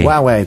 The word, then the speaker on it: Huawei: Huawei, thank you.